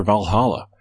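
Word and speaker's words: VA-11 Hall-A: Okay, so it's spelled VA-11 space Hall space or dash A.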